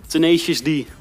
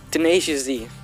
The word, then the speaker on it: Tenacious D: Hoe spreek je het uit? – Tenacious D. – Tenacious D.